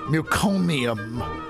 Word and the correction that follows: meconium: It's meconium, M-E.